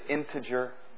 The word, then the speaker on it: integer: Is there an R in that integer? Integer. Oh, come on, help me. There's not. Is it integer?